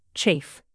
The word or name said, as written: chafe